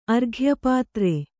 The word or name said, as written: ಅರ್ಘ್ಯಪಾತ್ರೆ